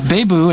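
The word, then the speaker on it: Babeu: So Pinal County, Arizona Sheriff Paul Bebu is hopping mad at the federal government.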